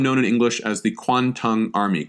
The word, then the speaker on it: Kwantung: Kwantung, K-W-A-N-T-U-N-G. The orthography of this name is kind of strange. What it is a Japanese pronunciation of the Chinese term for the general region. The Chinese pronunciation is Guangdong, east of the pass. East of the pass. Guangdong. And then the Japanese pronunciation of that is the Kanto, Kanto region.